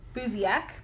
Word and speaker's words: Buziak: Okay, so, like I said, it's about Lindsay Buziak. That's how I heard it pronounced on all the things, so I'm pretty sure that's how it's pronounced.